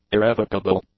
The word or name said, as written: irrevocable